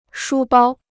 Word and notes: 书包: shū bāo

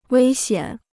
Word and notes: 危险: wēi xiǎn

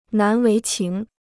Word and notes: 难为情: nán wéi qíng

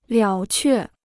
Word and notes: 了却: liǎo què

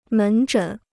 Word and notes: 门诊: mén zhěn

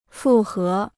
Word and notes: 复合: fù hé

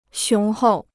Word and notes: 雄厚: xióng hòu

